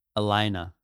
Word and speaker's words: Elayna: So you meet Elena, and then, by the way, you say her name different than I say it. You say it with an H. – Elena.